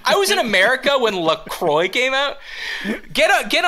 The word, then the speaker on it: LaCroix: The company insists it's pronounced LaCroix, but really, it should be LaCroix.